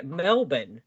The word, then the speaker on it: Melbourne: Yeah, and you have to say it like how we say it. Melbourne, not Melbourne. – Okay, I'm in Melbourne. Florida. – Melbourne. – It's not bored, it's bin.